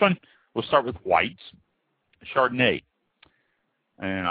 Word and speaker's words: Chardonnay: So, to start with here, Chardonnay, spelled C-H-A-R-D-O-N-N-A-Y, pronounced Chardonnay.